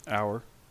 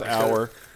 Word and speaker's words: Auer: Our. – Crower, okay. – No, no, it's Our. The C is his first name. His last name is Our. A-U-E-R. Just call him dipshit. – C-R Our over here.